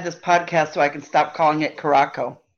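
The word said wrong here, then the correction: Curaçao: It's the same as the drink, Curacao. That's how you can remember it. The blue drink.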